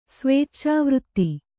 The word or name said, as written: ಸ್ವೇಚ್ಚಾವೃತ್ತಿ